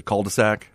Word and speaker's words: cul-de-sac: But I live on the end of a cuddle sack, too, and apparently I say that wrong. – It's a cul-de-sac? – Yeah.